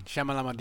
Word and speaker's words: Shyamalan: How do you pronounce it? Shyamalan? Okay. – Shyamalan. Shyamalan ding dong.